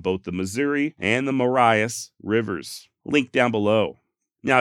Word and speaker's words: Marias: So in all actuality, if you want to be correct, it's Maria's River, not the Marias River. However, it does appear the Montana locals do call it the Marias, so I also chose to go with that pronunciation.